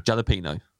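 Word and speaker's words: jalapeño: Jalapeno. – You say it differently to anybody I've ever heard saying that word. – You've obviously never heard a Spanish man say it, because that is the correct pronunciation.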